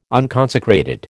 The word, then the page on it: unconsecrated: American English